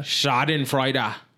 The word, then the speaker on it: Schadenfreude: Schadenfreude. That's how you say that. Still sounds wrong. How do you say it? Is that how you say it? Schadenfreude? – Schadenfreude.